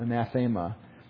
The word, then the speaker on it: anathema: And you'll have this word anathema, but it'll be anath, long E, aema, anathema.